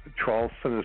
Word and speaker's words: Traulsen: Uh, uh again, my last name, Traulsen, is spelled T like Tom, R A U L S E N like Nancy, then put the word and, A N D, and then Trump, T R U M P.